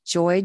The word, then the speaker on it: Joye: It's Mary, M-A-R-Y, Joye, J-O-Y-E, and you'll find me under LMHC, which is Licensed Mental Health Counselor.